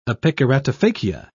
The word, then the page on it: epikeratophakia: British English